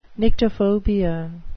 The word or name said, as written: nyctophobia